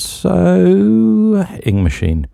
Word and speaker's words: So Ing Machine: They literally sent me a pronunciation guide which said upward inflection on so, with amused, disbelieving, dismissive tone, flat delivery of Ing Machine with as little delay as possible.